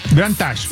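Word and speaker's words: Grand Taj: Para que, sí, la dirección, entonces para que ahí vayan en el Grand Tash. – Grand Tash. – Si lo quiere buscar en el Google Maps o en su aplicación de mapas, le pone Grand y le pone Tash. Se escribe T-A-J.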